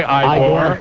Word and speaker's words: Igor: And incidentally, why don't you pronounce it the correct way? Igor.